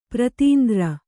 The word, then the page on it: ಪ್ರತೀಂದ್ರ: pratiindra